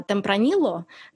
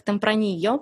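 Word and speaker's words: Tempranillo: по-русски мы называем а, темпронило, но по-испански он звуч- звучит как темпранио, потому что они не произносят двойную «л».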